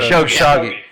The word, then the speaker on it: Khashoggi: Khashoggi.